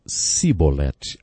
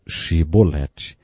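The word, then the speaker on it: Shibolete (שיבולת): Eles não conseguiam pronunciar o som CH e por isso diziam Sibolete, ao invés de Shibolete. Jefté degolou 42 mil homens, porque eles não conseguiram dizer Shibolete.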